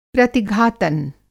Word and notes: प्रतिघातन: Hindi

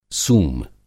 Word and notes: सूम: سُوم

